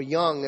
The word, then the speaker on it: Jung: There's a guy named Carl Jung. Or young, if you want to pronounce it.